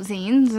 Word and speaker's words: zine: It's pronounced zine.